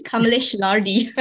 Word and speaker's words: Kamales Lardi: So let me just pronounce my name again, Kamales Lardi. um, it's part Malaysian and part Swiss the Lardi part is Swiss.